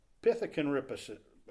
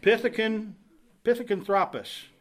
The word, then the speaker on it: Pithecanthropus: Another one is Australopithecus and Pithecanthropus. I said that wrong. Pithecanthropus. Australopithecus.